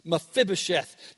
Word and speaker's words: Mephibosheth: You have to put the emphasis on the F, Mephibosheth.